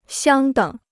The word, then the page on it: 相等: xiāng děng